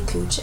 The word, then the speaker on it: Koch: So we see Sheriff Charlie Cooch and his wife Ella awake early in the morning. – I just want to say, his name's spelled like Coke, like K-O-C-H, but they call him like Cooch.